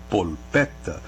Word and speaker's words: polpetta: E no caso da palavra que ele pergunta, polpetta, é assim que se diz em italiano, polpetta, que se escreve com L no meio e dois T's, polpetta, que é a nossa almôndega, é o nosso bolinho, né? – Uhum.